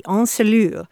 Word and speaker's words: ensellure: It's spelled E-N-S-E-L-L-U-R-E, encelure.